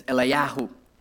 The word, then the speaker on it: Eliyahu: In Hebrew and in Greek, it was Elias, and in Hebrew, it was Eliyahu. See, if you want to call me in Greek form, it's Eliyahu. That's how you pronounce my name.